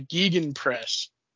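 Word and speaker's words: Gegenpressing: It's I believe it's pronounced the Gegen Press, G-E-G-E-N Press.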